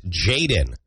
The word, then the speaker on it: JXDN: It is pronounced Jaden.